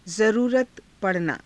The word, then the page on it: zaruurata pa ranaa: Hindi